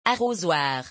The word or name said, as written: arosoir